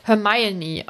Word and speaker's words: Hermione: Und im Englischen wird halt genau erklärt, dass es Hermione ausgesprochen wird, weil das war wohl irgendwie ein Ding.